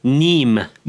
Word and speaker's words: Neame: Minu peamiseks saate külaliseks on Maik ja sinu perekonna nimi on Eestis kirjutatakse Neame. Kuidas sa hääldatakse? – Niim.